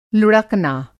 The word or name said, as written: लुड़कना